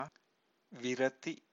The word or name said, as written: virati